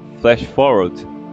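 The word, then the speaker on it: FlashForward: É o Flash Forward, é assim que pronuncia? – Flash Forward.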